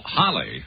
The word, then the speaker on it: Halley: We were mentioning a minute ago about Halley's Comet, or, well, what is the right pronunciation? According to the astronomer's own family, Halley of Stockton, California, it is Halley. Is that right? – We call it Halley, yes.